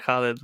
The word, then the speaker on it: Khalid: I was like, "Ooh, I've heard of Khalid so much that I forgot Khalid was like how you actually say it."